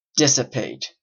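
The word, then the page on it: dissipate: Canadian English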